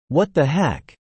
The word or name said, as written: What the heck